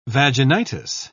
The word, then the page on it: vaginitis: British English